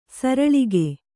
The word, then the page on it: ಸರಳಿಗೆ: saraḷige